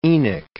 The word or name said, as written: Enoch